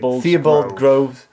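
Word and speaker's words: Theobalds Grove: Am I pronouncing it correctly? – Theobald Grove. Yes.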